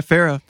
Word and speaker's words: Farro: Yeah, Pharaoh.